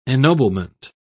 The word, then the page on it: ennoblement: British English